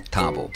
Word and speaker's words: Thabo: So is it Thabo or Thabo? – Thabo. – Thabo. So yeah. I've always been pronouncing it with the H, and I guess that's not correct.